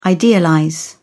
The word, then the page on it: idealize: British English